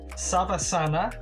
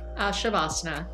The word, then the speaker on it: Savasana: Savasana, in parentheses, resting pose, or Andana, Balasana, happy baby pose. – Shavasana.